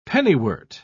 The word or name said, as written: pennywort